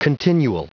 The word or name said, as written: continual